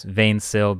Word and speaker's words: 20syl: which is also known as Vain Sill, because 20SYL is the spelling of it, but it's not English, so you pronounce it Vain Sill.